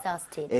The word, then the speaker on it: exhausted: Exhausted. – Exhausted. 약간 Z의 발음의 느낌으로 해서 발음 한번 해보도록 할게요.